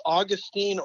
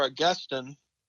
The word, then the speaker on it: Augustine: And by the way, is it do we pronounce this Augustine or Augustine? I like to use the example of my professor, Harold Honer. He just said it depends on which syllable receives the emphasis. – Yeah. – So both pronunciations are acceptable as far as I'm concerned.